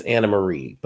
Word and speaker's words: Annemarie: We then get our first glimpse into guys, I believe her name is Anna Marie, but the way it's spelled is like Anne Marie, A-N-N-E-M-A-R-I-E.